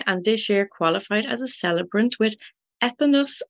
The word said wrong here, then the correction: Entheos: Ireland. Did I pronounce that correctly? Entheos. Entheos. – Entheos yes.